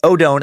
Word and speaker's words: Odone: Just so I wouldn't say like, now for another Odin, like the Norse god, I wrote Odone and I wrote rhymes with cone.